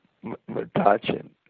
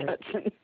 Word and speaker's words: Metuchen: And it just, it was just, I think it can't be that way in Metuchen. Did I pronounce it right? – No, I'm telling you that it's not just upstate. It's in Metuchen also. – Metuchen. Okay. – Metuchen, yes.